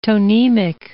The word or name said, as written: tonemic